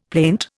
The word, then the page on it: plaint: Indian